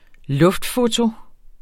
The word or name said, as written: luftfoto